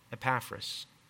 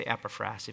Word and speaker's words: Epaphras: And he's Epaphras. Or you can say Epaphras if you like that emphasis on that syllable. Epaphras or Epaphras, a dear fellow servant and faithful minister of Christ, the one who is constantly praying for him.